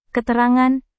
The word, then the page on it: keterangan: indonesian